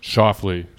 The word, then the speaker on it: Schauffele: Is that how you say it? Schauffele? – Schauffele. – Schauffele. So yes would have been the answer? – Correct. – Okay. All right. I did say it right.